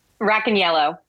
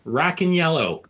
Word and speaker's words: Racaniello: And Vincent, how do you pronounce his last name? Roncalo? – Rack and yellow. – Racaniello.